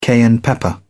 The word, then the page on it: cayenne pepper: British English